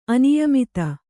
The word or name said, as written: ಆನೆಯಮದ